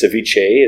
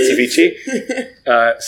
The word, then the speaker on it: ceviche: I pronounce it like the DJ. I think it's ceviche is really what you're supposed to say, not ceviche.